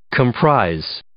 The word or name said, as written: comprise